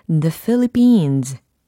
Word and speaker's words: Philippines: 아, 어, 여러 가지 발음들이 있는 것 같은데 정확한 발음은 바로 이겁니다. The Philippines. The Philippines. 이렇게 해주셔야 되거든요.